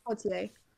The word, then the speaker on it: Poitier: Then a couple of days ago, we lost Sidney Poitier. Poitier, I'm bad with pronunciation. – Poitier, that sounds about right. – It's Poitier.